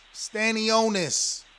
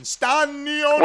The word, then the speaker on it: Stanionis: His name is Stanionis. Stanionis. That's the pronunciation. Stanionis.